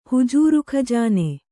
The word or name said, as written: ಹುಜೂರುಖಜಾನೆ